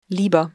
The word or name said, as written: lieber